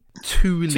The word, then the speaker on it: Thule: I got a, I think it's pronounced Thule. – Thule, yeah, that's a brand.